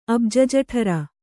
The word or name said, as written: ಅಬ್ಜಜಠರ